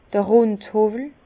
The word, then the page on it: d'r Rundhowwel: Bas-Rhin; Herrlisheim